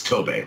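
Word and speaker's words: Tobe: What I've always really found interesting about the Texas Chainsaw Massacre is it's Tobey, right? Tobey Hooper? That's how you pronounced it when he was still alive? – Yeah.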